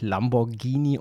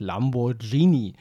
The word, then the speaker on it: Lamborghini: Erstmal habe ich letzte Woche das Wort Lamborghini falsch ausgesprochen. Es heißt natürlich Lamborghini und nicht Lamborghini liegt noch in meiner Kindheit.